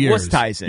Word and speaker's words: Oosthuizen: It's really Oosthuizen.